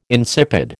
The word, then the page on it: insipid: American English